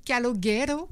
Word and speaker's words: Calogero: Calogero. – Oh. – C'est pas espagnol, c'est italien.